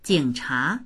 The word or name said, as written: jing3cha2